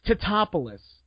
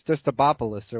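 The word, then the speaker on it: Tatopoulos: It's not a difficult name to say. If you look up the spelling, oh, really? – It's pronounced phonetically. It's T-A-T-O-P-O. It's phonetic. It's Titopoulos. It's not hard to say.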